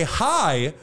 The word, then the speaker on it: Hej: It's spelled H-E-J, but it is pronounced the same way as hi here in English.